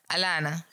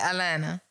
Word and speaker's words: Atlanta: Alana. That's how they say it, Alana.